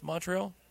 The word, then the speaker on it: Montreal: It's Montreal.